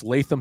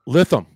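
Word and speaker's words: Lytham: It's Latham St. Anne's, right? – Litham. – Okay. Darn it. Yeah. Got it wrong, but that's why I got you on.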